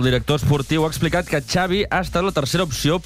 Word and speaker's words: Xavi: Xavi Hernández. – Xavi. – Okay. Yeah, Xavi like chocolate.